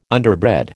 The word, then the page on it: underbred: American English